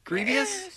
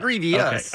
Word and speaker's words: Grievous: Grievous? Grievous, but yeah. – Grievous? – Yeah, you had an extra syllable.